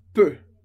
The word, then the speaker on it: peut: A gente viu je peux, tu peux, il, elle, on peut. Lembra? Terminação em x para je, x para tu, t para il, elle, on, mas a pronúncia é exatamente a mesma. Peut, peut, peut.